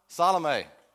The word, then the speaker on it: Salome: Salome. That is the correct pronunciation.